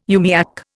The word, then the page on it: umiak: Indian